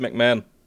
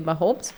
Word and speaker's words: McMahon: Vince McMahon. Also es wird McMahon ausgesprochen. – Ich kenne nur Mahomes, deswegen ist er bei mir Mahomes.